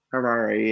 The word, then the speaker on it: Harare: So, Rua is near, pronunciation, Harare. – Yeah, I think it's Harare. – Harare, yeah.